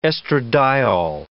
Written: estradiol

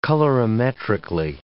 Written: colorimetrically

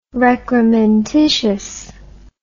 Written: recrementitious

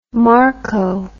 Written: marco

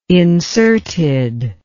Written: inserted

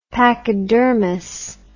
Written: pachydermous

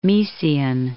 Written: Miesian

